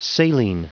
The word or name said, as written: saline